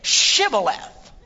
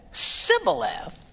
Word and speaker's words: Shibboleth: And they'd say to him, "Say, all right, then say Shibboleth." And if he said Sibboleth, because he couldn't pronounce the word correctly, they'd seize him and kill him.